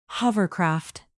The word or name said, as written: hovercraft